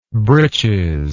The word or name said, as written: breeches